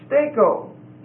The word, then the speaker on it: steko: The word stand fast here, to stand fast therefore in the liberty, is steko. S-T-E-K-O. It's a long E and a long O. S-T-E-K-O. Steko.